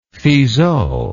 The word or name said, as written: fizeau